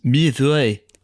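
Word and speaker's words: mille-feuilles: Mille feuilles. I really do like that. – They're not the best ones. Why do you say mille feuilles like that? It's really because of the correct pronunciation.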